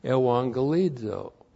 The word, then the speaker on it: euangelizo: looks like that in the Greek, E-U-A-N-G-E-L-I-Z, long O, Ewangelizo.